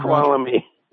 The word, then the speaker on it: Snoqualmie: Snoqualmie.